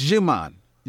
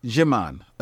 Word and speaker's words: Djimon: And it's Jimon. Jimon. The D is silent.